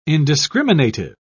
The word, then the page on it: indiscriminative: British English